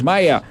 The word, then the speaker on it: Meyer: está George Maya. Se escribe Meyer, si lo quieren buscar, porque está compitiendo.